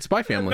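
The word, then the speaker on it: Spy × Family: Do you pronounce the X in Spy X Family? – No. – No. – It's Spy Family.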